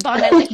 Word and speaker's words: Bondi: I'm sorry, was I saying it wrong? Bondi Bowls. – That's okay. Bondi People Bowls. Say Bondi all the time, and I'm like, hey, whatever. Live your life. It's Bondi, like Bondi Beach, Australia.